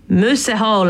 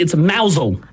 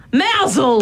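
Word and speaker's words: Mousehole: Oh, it's actually mousel. – Mousel. – But it's spelt mouse hole. – It is spelt like mouse hole.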